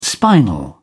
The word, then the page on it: spinal: British English